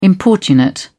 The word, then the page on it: importunate: British English